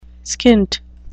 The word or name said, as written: skint